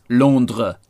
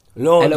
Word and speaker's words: Londres: For the French, it's Londres. – Londres. – L-O-N-D-R-E-S. Like, they even spell it different.